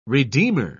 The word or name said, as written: redeemer